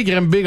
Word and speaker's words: Granby: G-R-A-N-B-Y. – D'ailleurs, y a-tu quelqu'un qui veut m'expliquer pourquoi on prononce pas ça Grimbé? – C'est parce que ça s'appelle Grimbé.